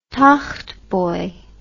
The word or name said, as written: togtboy